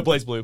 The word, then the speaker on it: BlazBlue: I think it's pronounced Blaz, right? – Yes. Oh, no, BlazBlue. BlazBlue.